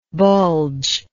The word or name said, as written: bulgingly